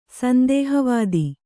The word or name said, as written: ಸಂದೇಹವಾದಿ